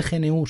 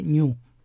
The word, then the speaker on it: GNU: Vale, los puretas me diréis que no se dice GNU, se dice New pero bueno.